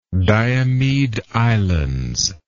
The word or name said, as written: diomede islands